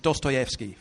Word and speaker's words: Dostoevsky: Number one, as it's Dostoevsky, first of all. That's how you pronounce his name.